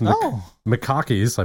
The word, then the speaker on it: macaque: Japanese macaques, I believe I'm pronouncing that. – Excuse me? You got macaques on the brain. – Macaque. M-A-C-A-Q-U-E-S. That's macaque, right? – What are they?